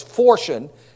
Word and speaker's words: phortion: It is pronounced, it looks like portion with an H. So it's fortion, P-H-O-R-T-I-O-N, the Greek word for this.